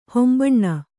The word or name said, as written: ಹೊಂಬಣ್ಣ